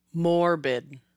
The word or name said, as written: Morbid